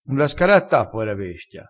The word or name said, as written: scaratta